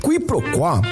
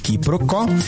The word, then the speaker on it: quiproquó: Quiproquó. Q-U-I-P-R-O-Q-U-O com acento agudo. Quiproquó também informalmente pronunciada como quiprocó, é a ação de confundir uma coisa com outra, ou o desentendimento decorrente dessa confusão.